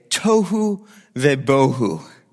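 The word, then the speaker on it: tohu vebohu (תֹהוּ וָבֹהוּ): So formless, this is, in Hebrew you say, tohu vebohu.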